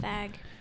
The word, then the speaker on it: Feig: Is his last name Feig? Paul Feig or Feig? I never know how to pronounce his last name. – Fag. That's it.